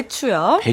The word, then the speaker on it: 배추: 대추가 아니라 배추예요. 우리 희순 씨가 발음이 조금 그랬어요. 네. 배추요.